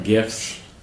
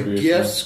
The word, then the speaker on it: GIF: I know it's pronounced GIF, but... – I say GIF. – I say GIF, too. – It's graphics interchange format. That's a hard G.